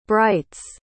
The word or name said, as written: brites